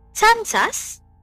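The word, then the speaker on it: tsantsa: And the local people that are known to make these shrunken heads call these small heads as tsantsas, which is T-S-A-N-T-S-A-S, tsantsas.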